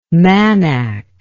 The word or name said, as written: manak